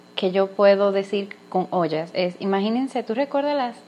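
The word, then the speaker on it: ojas: Así que para aclarar se escribe O-J-A-S y se dice ollas.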